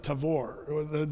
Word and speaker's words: Tabor: But we will drive by Mount Tabor. The Jews pronounce it Tavor. The B is often a V.